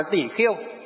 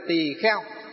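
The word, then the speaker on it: tỳ kheo: À, nhưng miền Bắc chúng ta đọc là tỷ khiêu, miền Nam là tỷ kheo.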